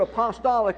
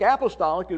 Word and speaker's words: apostolic: And I say the word apostolic rather than apostolic. Apostolic is really the correct pronunciation of the word, but since there has so many denominational things tied in with that, I simply use my own pronunciation, apostolic, that from the apostles.